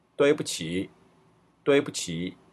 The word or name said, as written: duibuqi